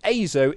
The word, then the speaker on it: Azo: And the A in Azo is pronounced just like the letter A, like ache and apron. So yeah, Azo.